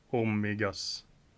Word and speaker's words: hormigas: El Niño esta comiendo hormigas. – Bra! El Niño está comiendo hormigas. – Hormigas. – Mm. Kom ihåg, okay, att H-et uttalas aldrig.